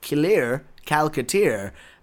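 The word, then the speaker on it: Killeur Calculateur: and the name of the show is Kilir Kalkatir. It's very hard to explain how that it's K-I-L-L-E-U-R-C-A-L-C-U-L-A-T-E-U-R. It sounds like a French stereotype saying Killer Calcutta.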